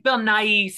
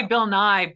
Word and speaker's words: Nighy: Yeah, I think it's Bill Nighy. Some people say Bill Nye, but that's like Bill Nye the Science Guy. So I always like to add the E for Bill Nighy.